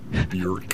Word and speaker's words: Björk: Björk.